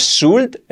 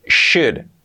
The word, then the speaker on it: should: Importante la pronunciación, que no es should, es should, should, should.